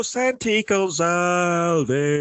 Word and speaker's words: Santi Gonzalez: Oh, Santi Gonzalez.